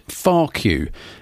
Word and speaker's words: Farcue: So there they were, Froome's finest and longest running punk band, Farcue. And if you don't know, that's spelt F-A-R-C-U-E, two words.